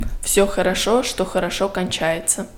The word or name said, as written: все хорошо что хорошо кончается